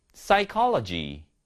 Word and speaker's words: psychology: Psy, Psy, Pseudonym, Pseudonym, Pneumonia, Pneumonia, Psychology, Psychology. 자, 피디님, 공통점이 뭐죠? 너무 기습 질문인가요? 자, 일단은 공통점은 P 발음이 전부 다안 난다라는 것이죠. 그래서 P 다음에 바로 S가 오거나 바로 N이 오면은 무음인 경우가 많습니다.